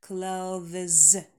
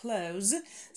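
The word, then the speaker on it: clothes: Несмотря на то, что вполне себе базовое простое слово, но очень часто на нем попадаемся, потому что на стыке межзубный звонкий и далее звонкий «з». «Клавез», «клавез». Неудобное произношение, поэтому я советую вам всем произносить это слово как close. Самый удобный вариант, его допускает словарь, и если у нас есть легальная возможность избавиться от неудобных межзубных звуков для русскоязычного говорящего, то почему бы и нет?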